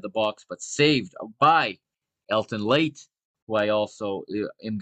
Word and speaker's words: Helton Leite: And shout out to to to my new friend there, uh, my new friend Nino Torres, uh, pronouncing it Helton Leite. He's got the Brazilian pronunciation there,